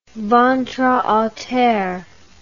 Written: ventre a terre

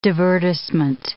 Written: divertissement

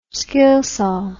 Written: skilsaw

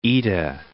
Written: Oeta

